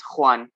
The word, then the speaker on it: Juan: Well, you got TinDefi and ScanDefi right, but my name, it's hard to pronounce. It's Spanish, and it's very, always everyone gets it wrong. It's Juan, but you know, don't worry about it.